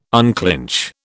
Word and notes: unclinch: British English

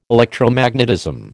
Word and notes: Electromagnetism: American English

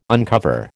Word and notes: uncover: American English

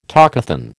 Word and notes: talkathon: American English

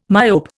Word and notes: myope: Indian

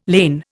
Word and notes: lane: Indian

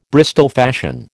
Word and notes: Bristol fashion: American English

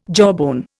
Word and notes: jawbone: Indian

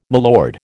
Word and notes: milord: American English

